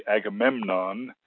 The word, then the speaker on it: Agamemnon: So, for example, you see Agamemnon with its accent on the third syllable, but you also see Andromache with the accent on the second syllable.